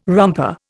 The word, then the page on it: romper: Indian